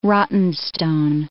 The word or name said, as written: rottenstone